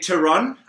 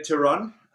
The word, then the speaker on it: Theron: I say Theron. In South Theron? Africa, we say Theron. Theron, there's nothing wrong with it.